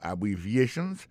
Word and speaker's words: abbreviations: Abbreviations, accent tonique là, tombé sous troisième syllabe là.